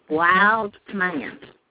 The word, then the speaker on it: Wildman: W-A-Y? – No, it's W-I-L-D-M-A-N, Wildman.